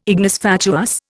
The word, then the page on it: ignis fatuus: Indian